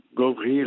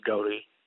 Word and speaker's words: Govri: Les Français disent Govri, les anglophones disent Gauri.